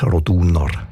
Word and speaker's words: Roduner: Also üblicherweise betonen wir ja Namen in der Deutschschweiz, wo drei Silben haben, immer auf der ersten Silbe. Also Andereck, Zollinger, Bertschinger, Ochsenbein. In dem Fall ist es aber eher die zweite Silbe, die betont Roduner.